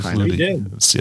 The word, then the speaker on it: Flutie: Hey, Doug Hootie, is that how you pronounce it? He made it into the NFL. – Doug Flutie. Yes, he did.